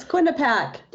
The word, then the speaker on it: Quinnipiac: It's Quinnipiac.